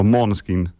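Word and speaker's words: Måneskin: Maneskin. Or Maneskin, actually, if you do the correct Danish pronunciation of the name.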